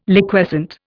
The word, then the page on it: Liquescent: Indian